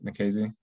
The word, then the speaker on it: Nikhazy: Trying to remember, one of those home runs was against Doug Nikhazy. That's how you say it, right? Nikhazy? – Yeah, and that's one of our guests.